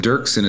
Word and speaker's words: Doerksen: So Brian is with an I, not Y, so B-R-I-A-N, and then Dirksen is spelled D-O-E-R-K-S-E-N. So the O is silent, but it's definitely there in the spelling.